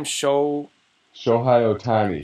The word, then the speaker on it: Shohei Ohtani: Shohei Ohtani.